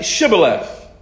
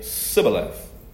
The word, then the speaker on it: Shibboleth: Well, they said to him, "Well, say Shibboleth." And he would say Sibboleth, for he could not pronounce it right.